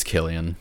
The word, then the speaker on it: Cillian: I can't contest that in the slightest, but I can't pronounce his name, but the lead actor, is it Cillian Murphy or Cillian Murphy? – I believe it's Cillian.